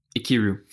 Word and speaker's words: Ikiru: I'm not sure on the pronunciation. Ikiru. Do you have a pass on that? – Ikiru. – Okay, there we go. It's I-K-I-R-U. Ikiru.